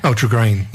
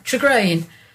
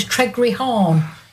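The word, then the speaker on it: Tregrehan: And there was a place just outside St Austell called Tregrain. Yes. – Yeah, Tregrain. Okay, it's spelt a bit weird, but you listen to a non-Cornish pronounce it, it comes out as Tregreehorn.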